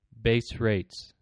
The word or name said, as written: base rate